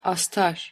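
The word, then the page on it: astar: Turkish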